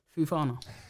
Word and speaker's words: Fofana: Well, how do you say it? – Fofana.